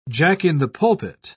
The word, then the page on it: jack-in-the-pulpit: British English